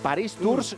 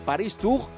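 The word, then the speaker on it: Paris-Tours: Paris Tours se escribe. Paris Tour se pronuncia.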